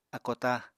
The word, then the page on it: ákótá: Apatani